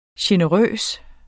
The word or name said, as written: generøs